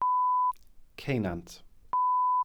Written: ceunant

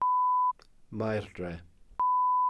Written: maerdre(f)

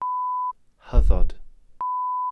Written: hyddod

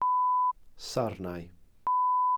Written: sarnau

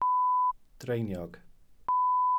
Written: dreiniog

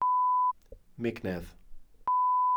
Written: mignedd

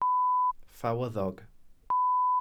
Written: ffawyddog

